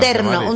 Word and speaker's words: terno: Tú ya sabes lo que es un tecno porque tu madre te lo ha dicho. – Sí, ¿qué? Pero pronúncialo bien, porque es que no, antes lo habíamos terno, un terno. – Un terno con R.